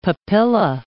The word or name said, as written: papilla